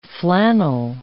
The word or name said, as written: flannel